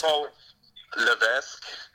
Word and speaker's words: Levesque: Levesque? – Paul Levesque.